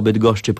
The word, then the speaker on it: Bydgoszcz: W Polsce jednak człowiek szybko się przyzwyczaja do połączeń ze szcz, co w angielskim wymawia się po prostu jako sz, Bydgoszcz.